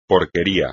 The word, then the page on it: porquería: espagnol